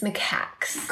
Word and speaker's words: macaques: I'm saying that wrong. I was saying macaques, but it's macaques, isn't it? – It's macaques.